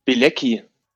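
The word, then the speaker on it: Bielecki: Wie wird der wirklich ausgesprochen? – Bilecki.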